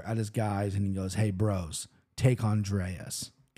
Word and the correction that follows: Andrade: Okay, can't even pronounce her name right, Andrade.